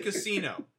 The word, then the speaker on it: Casino: Does it rhyme with Pacino? – Yes, it does rhyme with Pacino. I just said Casino, and I think that is my absolute favorite, and I think it's actually the best mafia movie of all of them.